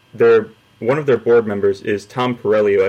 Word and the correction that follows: Perriello: I believe I'm pronouncing that correctly. Hey, yeah, I think it's Perry. I think Perriello.